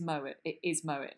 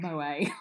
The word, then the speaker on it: Moët: it is Moët, less Swiss, it's not Moët, like, you're all heathens, I'm pronouncing it right.